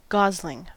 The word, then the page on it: gosling: American English